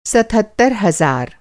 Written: सतहत्तर

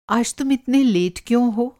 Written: लेट

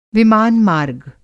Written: विमान-मार्ग

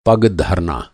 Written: पग

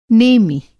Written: नेमि

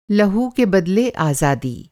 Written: लहू

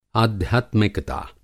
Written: आध्यात्मिकता

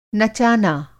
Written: नचाना

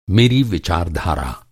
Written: विचारधारा

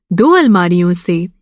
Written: अलमारी

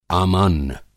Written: आमान्न

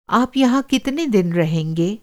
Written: रहना

